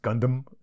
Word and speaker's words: Gundam: But yeah, it's Gundam, I guess is how I always pronounce it. But Gundam is another way.